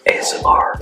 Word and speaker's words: ASMR: MSR, ASMR.